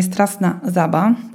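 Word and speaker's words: Straszna Żaba: zostało Stowarzyszenie Strasna Zaba i dokładnie tak się je wymawia, z Płochocina.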